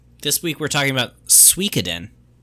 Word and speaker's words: Suikoden: The story of Suikoden is loosely based on Shui Hu Zhuan, Water Margin, a classic Chinese novel, the title of which, when pronounced in Japanese, is Suikoden.